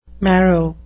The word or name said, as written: marrow